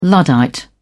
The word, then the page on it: luddite: British English